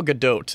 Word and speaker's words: Gadot: Is it Gal uh, Goddard? Gal Gal Gadot is uh, the correct pronunciation.